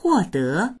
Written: huo4de2

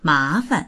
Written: ma2fan

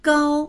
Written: gao1

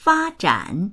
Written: fa1zhan3

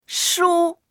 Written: shu1

